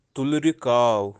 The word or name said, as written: tulri-kaav